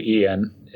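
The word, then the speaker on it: Iain: BYU Ian is my name on there.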